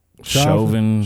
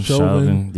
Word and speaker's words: Chauvin: Derek Chauvin. Chauvin, yeah.